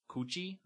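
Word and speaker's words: kuchi (口): What really happened was he took one of the characters, the Japanese characters for mouth, which is the word kuchi. I might be pronouncing that wrong. – That sounds right again. I think you nailed it.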